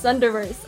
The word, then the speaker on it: Thunderverse: Universe or Zunderverse? – Oh, it's like Thunder. Thunder. It's Thunderverse. I mess it up.